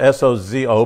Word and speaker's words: sozo: It's spelled S O Z O, but it's pronounced with a D E S O D E, sozo, and it means safe, deliver, and protect.